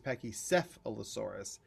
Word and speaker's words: Pachycephalosaurus: The other day I said Pachycephalosaurus, and he was like, it's Pachycephalosaurus.